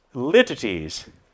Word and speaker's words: litotes: It's spelled L-I-T-O-T-E-S. Looks like litotes, right? But it's not. It's pronounced lytides.